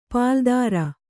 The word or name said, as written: ಪಾಲುದಾರ